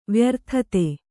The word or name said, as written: ವ್ಯರ್ಥತೆ